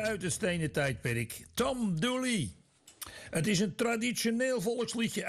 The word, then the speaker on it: Dula: En ze werd vermoord door Tom Dula, wiens naam in het lokale dialect Dooley werd uitgesproken.